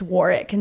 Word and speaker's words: Warwick: It's written as it's Warwick, but it's pronounced Warwick. And we got told like a million times it's Warwick.